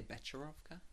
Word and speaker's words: Becherovka: Bekrovka. How would you pronounce it? – It's, I would say Becherovka.